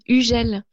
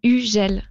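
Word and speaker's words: Hugel: Yeah, the pronunciation is Ugel. You don't say the H and the G is like J. So Ugel.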